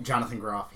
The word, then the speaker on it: Jonathan Groff: And the movie stars Kristen Bell as Anna, Idina Menzel as Elsa, Jonathan Groff, is that how you say it? – Jonathan Groff, yeah.